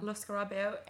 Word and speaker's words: Lo Scarabeo: They're called Lescarabeo? – Yeah. – Lo Scarabeo.